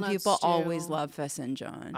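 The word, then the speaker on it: fesenjan: Do you like the fezenjan? Is that, am I pronouncing that? – Yeah.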